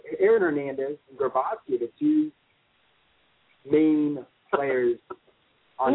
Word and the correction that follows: Gronkowski: Am I saying his name wrong? – This is Gronkowski.